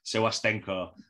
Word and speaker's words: Sewastenko: Today, I am joined by the one and only Jess, I hope I say this right, Sewastenko.